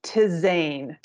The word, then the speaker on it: tisane: But herbal teas are tisane, that's how you say it.